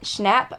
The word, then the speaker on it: Schnapp: Schnapp. I meant maybe that's how you say his name. Um, Schnapp. It's like schnapps, but without the s.